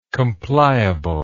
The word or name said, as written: compliably